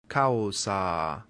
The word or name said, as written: causa